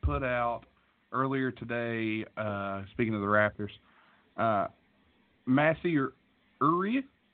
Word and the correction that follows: Ujiri: is that how you pronounce that name? – Ujiri? – Canada, yeah. – Massey Ujiri, yeah.